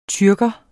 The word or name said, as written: tyrker